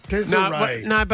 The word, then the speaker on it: Desree: Desray. – But it's spelt D-E-S-R-E-E, Kate. So it's Desray.